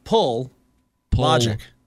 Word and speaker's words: Pelagic: Say his name for me, please. – Pelagic. – Pelagic. – Yeah, pull logic. – Pull logic. – Pull logic. – That is not how it's spelled. – Not even close.